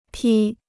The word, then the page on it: 披: pī